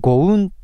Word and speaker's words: GOUNN: これね、 G-O-U-N-N、大文字で G-O-U-N-N と書いて、ゴウンと読みますね。